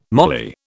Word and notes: mali: British English

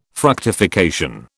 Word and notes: fructification: British English

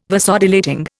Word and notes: Vasodilating: Indian

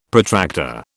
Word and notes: Protractor: British English

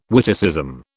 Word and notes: witticism: British English

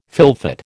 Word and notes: fylfot: American English

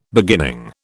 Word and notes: beginning: British English

